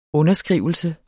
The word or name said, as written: underskrivelse